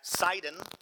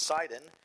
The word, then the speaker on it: Sidon: The next day we put in at Sidon, is how that's pronounced, Sidon, and Julius treated Paul kindly and gave him leave to go to his friends and be cared for.